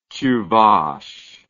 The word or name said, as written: Chuvash